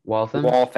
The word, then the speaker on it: Waltham: Kyle from Waltham? Is that how you say it? – Waltham.